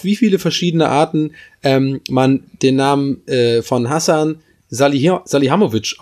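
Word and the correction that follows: Salihamidžić: Also, Hasan sag mal, wie der heißt. – Salihamidzic, genau. So, deswegen kam ich gerade durcheinander. Ich habe es falsch gesagt.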